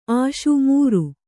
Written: ಆಶಿ

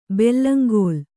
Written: ಬೆಲ್ಲಂಗೋಲ್

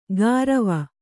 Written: ಗಾರವ

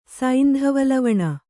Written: ಸೈಂಧವಲವಣ

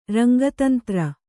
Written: ರಂಗತಂತ್ರ

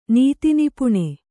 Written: ನೀತಿನಿಪುಣ

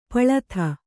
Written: ಪೊಲದಿ

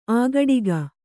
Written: ಆಗಡಿಗ

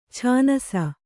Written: ಛಾನ್ಸ

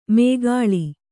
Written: ಮೇಗಾಳಿ

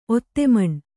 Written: ಒತ್ತೆಮಣ್

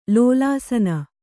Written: ಲೋಲಾಸನ

